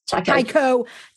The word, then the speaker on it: Taiko: But the Taiko, I forget how you say it. – Taiko.